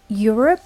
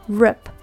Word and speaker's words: Europe: And the word Europe, R-O-P-E, is not pronounced as rope, but rip. Europe.